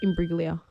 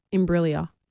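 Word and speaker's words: Imbruglia: Imbriglia. – Soft G. – Imbriglia. – Bang. – Imbriglia. Natalie Imbriglia.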